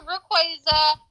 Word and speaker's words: Rayquaza: I told you, Rayquaza.